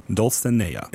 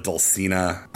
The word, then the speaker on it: Dulcinea: At least I think the correct way to say it is Dulcinea instead of Dulcina.